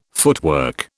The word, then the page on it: footwork: British English